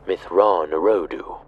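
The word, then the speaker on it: Mitth'raw'nuruodo: It's pronounced Mithran Urodu.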